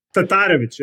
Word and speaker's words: Tatarevic: He's going to pronounce the name for you. He's, he's a It's pretty, pretty close. – Was that it's close? yeah. – Tatarevic, yeah. So it's just just got to do that itch at the end, but pretty close.